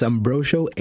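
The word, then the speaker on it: Ambrocio: It's Ambrosio, A-M-B, as in boy, R-O-C-I-O.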